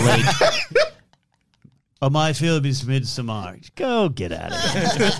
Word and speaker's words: Midsommar: Is that how you say it? Midsommar? – Yeah, I think it's the director has said Midsommar, The director himself has said it.